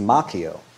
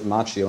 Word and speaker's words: Macchio: Daniel-san, played by Ralph Macchio, which is actually the Italian way pronounced Macchio. But I believe he pronounces it Macchio, and I'll respect his decision.